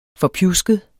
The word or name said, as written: forpjusket